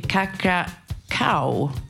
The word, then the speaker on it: Kokroko: frågetecken angående låtens titel och hur den ska uttalas. Det stavas kokroko. Jag bollar lite med min högra hand och pe backup-Ivar. Och vi kom fram till Kakra Cow med The Actions.